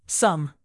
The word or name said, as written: sum